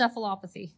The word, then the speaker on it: encephalopathy: It's encephalopathy.